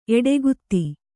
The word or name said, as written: ಎಡೆಗುತ್ತಿ